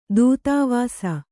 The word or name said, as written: ತೊತ್ತುವೆಸ